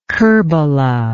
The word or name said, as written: kerbela